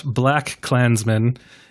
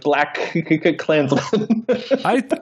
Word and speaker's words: BlacKkKlansman: I feel like you just emphasize the second syllable a little bit, don't you? That's how I've been saying it. Like, I was doing, like, BlacKkKlansman.